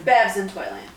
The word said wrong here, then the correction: Babes in Toyland: Babes in Toyland, I think is how it's pronounced. I think it is pronounced Babes in Toyland.